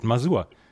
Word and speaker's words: Masur: das wird so geschrieben gesprochen, wie es geschrieben wird, Masur.